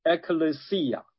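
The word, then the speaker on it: Ecclesia (ἐκκλησία): 那个字啊，在希腊文里面发音叫做 Ecclesia 啊。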